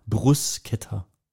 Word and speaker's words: Bruschetta: Also Betonung liegt auf dem Brus und dann K-E-Ta. Bruschetta.